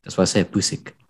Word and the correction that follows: Pulisic: No, Pulisic. – Do I have to say it like that? Pusik. – No, Pulisic. It's with a U.